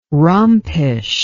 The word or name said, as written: rompish